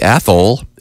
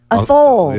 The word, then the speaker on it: Athol: How would they pronounce it? – Athol. – Yeah, maybe even Athol. – Yeah, maybe. – You mean the H is silent?